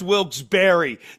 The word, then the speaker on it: Wilkes-Barre: I love this town so much, I know it's pronounced Wilkes-Barre. Yes.